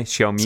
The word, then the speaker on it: Xiaomi: So the Chinese smartphone company, which I'm going to mispronounce, Xiaomi. Is that how you pronounce it? X-I-A-O-M-I? Xiaomi? Something like that? – I make it a Z. Xiaomi.